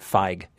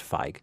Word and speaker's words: Feig: No one called me on this, but I found out later that I was mispronouncing the name of the director of The Heat. His name is Paul Feig. I kept pronouncing it Feig. It's F-E-I-G, so I assumed it was Feig. But in fact, it is Feig.